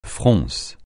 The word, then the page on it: fronce: francais